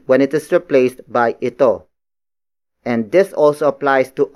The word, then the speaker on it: ito: Nabasag is spelled as N-A-B-A-S-A-G. It means broke, or the event of breaking has happened. And then it is followed by ito. Ito. Ito. Ito is spelled as I-T-O.